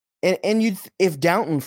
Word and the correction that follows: Doughton: for example, was on an exhibit 10, sorry, that it's not how it's Doughton. What's how Doughton. – Am I pronounce Doughton?